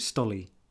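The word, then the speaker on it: Stoli: Well, this week, there's another pronunciation issue. We, as Charlie's drinks in the film almost exclusively, we are having a glass of Stolly. I'm going to call it Stolly. – Yeah, definitely Stolly.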